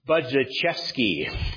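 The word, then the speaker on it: Budziszewski: I believe his name is pronounced Budzichevsky.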